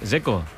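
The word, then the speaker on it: Džeko: Dzeko.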